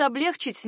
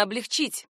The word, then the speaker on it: облегчить: Вариант облегчить неверный, правильно облегчить.